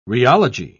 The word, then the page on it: rheology: British English